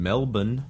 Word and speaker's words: Melbourne: And then our buddy Aiden, who is an Australian in Melbourne. Melbourne is how you say that correctly. Melbourne. Not Melbourne or Bern. Bun. Melbourne.